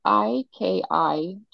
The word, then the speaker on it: Ikigai: And then Ikigaya is, I don't know if I'm saying that right. It's like the Japanese secret to a long and happy life. – Oh, okay. – It's Can you spell that for us? – yes, Ikigai. – Ikigai.